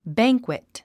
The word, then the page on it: banquet: bǽŋkwit; バァンクイット